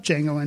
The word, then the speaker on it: Django: Best Picture, in alphabetical order, Amour, Argo, Beast of the Southern Wild, Django Unchained, because it starts with a D, but that D is silent.